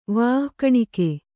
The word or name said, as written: ವಾಃಕಣಿಕೆ